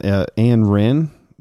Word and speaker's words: Ayn Rand: I feel like it also gets a little, like, what is it, Ayn Rand?